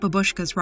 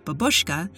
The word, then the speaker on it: babushka: Also note that, while in English we put the stress on the second syllable, babushka, in the original Russian, the stress is on the first syllable, babushka.